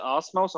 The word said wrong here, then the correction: Omos: almost. – Omos.